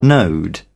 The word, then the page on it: node: British English